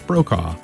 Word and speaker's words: Brokaw: After that we had, Chris Brocka? How do you say Chris? Chris Brokaw.